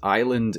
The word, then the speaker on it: island: And probably the favorite one out of this list is island and isle. That's I-S-L-E, isle. Nothing to do with each other, despite referring to the same thing, and both of them having a silent S.